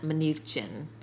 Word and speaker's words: Mnuchin: Steven Mnuchin.